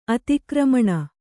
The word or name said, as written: ಅತಿಕ್ರಮಣ